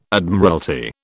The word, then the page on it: Admiralty: British English